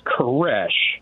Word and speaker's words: Koresh: And the Hebrew pronunciation of Cyrus is Koresh.